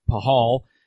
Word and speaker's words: Pahal: But a guy like Braden Pahal, it looks like Pashtal, but it's pronounced Pahal.